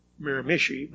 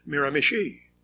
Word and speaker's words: Miramichi: The location is not pronounced Miramichi, but Miramichi. I do tend to default to penultimatism, UOS.